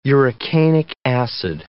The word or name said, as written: urocanic acid